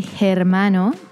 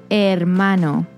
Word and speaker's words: hermano: Remember that the H is silent in Spanish. So we don't say hermano, we say hermano.